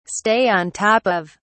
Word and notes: stay on top of: British English

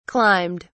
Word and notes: climbed: British English

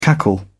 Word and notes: cackle: British English

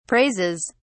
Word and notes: praises: British English

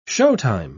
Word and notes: showtime: British English

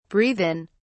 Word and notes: breathe in: British English

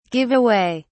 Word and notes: give away: British English

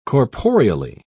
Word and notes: corporeally: British English

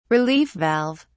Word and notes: relief valve: British English

